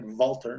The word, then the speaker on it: WALTER: That's how you say it, Walter, not Walter. Walter.